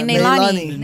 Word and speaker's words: Nalani: N-A-L-A-N-I. – Neilani.